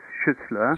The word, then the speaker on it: Schüssler: And the German pronunciation is Schüssler.